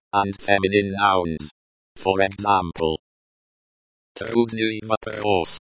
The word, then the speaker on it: Трудный: Трудный, the y at the end of the word is pronounced like the y in yes, and the u is pronounced like the u in moon.